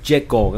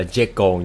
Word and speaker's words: Jekyll: Hôm nay chúng ta lại tiếp tục trở lại với quyển sách Những Âm Mưu Từ Đảo Jekyll các bạn. Cái chữ này đọc là Jekyll nha, chứ không phải Jekyll.